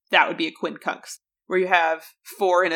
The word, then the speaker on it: quincunx: That's Q-U-I-N-C-U-N-X. I had to look up how you pronounce this word. – Yeah, that's a funky word. – What it meant, because it ends in an X. I was like, how do you say that? Quincunx.